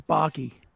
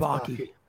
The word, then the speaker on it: Bacci: It's Bacci. – Well, not to me. – It's Bacci. – All right, fine, Bacci. Either like way, Bacci. It ends with an I. – Rhymes with hockey.